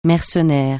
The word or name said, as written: mercenaire